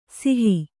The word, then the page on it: ಸಿಹಿ: sihi